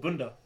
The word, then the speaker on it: bunda: Bunda. As in, like, B U N D A.